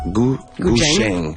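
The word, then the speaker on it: guzheng: Ça se prononce Gujeng. – Ouais, Gujeng. C'est plus un CH, un TCH, mettons.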